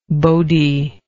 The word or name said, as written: bodhi